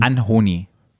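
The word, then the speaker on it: Anohni: Anholy oder Anhony? – Anhony. Anhony nennt er sich jetzt.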